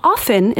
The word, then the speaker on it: often: It is often, the no T in the dictionary, or sorry, it's often in the dictionary. The T is silent.